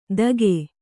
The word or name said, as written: ತಗೆ